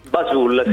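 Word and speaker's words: Buzzoole: Si pronuncia? – Bazul. È un'azienda di influencer marketing. – Che si scrive Buzzole, Bazul.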